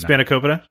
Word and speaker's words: Spanakopita: Sp- spin- Spanakopita.